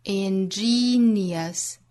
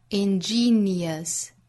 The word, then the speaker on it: ingenious: 还有一个词是 ingenious 第二个音节也是一个紧音长音，也是 e 的发音，这个词发成 ingenious 还要注意后面的 ous 不要读成卷舌的 r，是 schwa sound ingenious，我们要注意这两个词的发音，还有一些元音的连读，比如说 the beauty of diversity is，这都属于元音和元音连读之间的小小的半元音耶来衔接的小技巧。